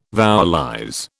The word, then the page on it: vowelize: British English